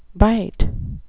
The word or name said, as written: bight